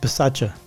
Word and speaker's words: Bisaccia: I don't think you pronounce the second I. I think it's just Bisaccia. That's the way I've heard it.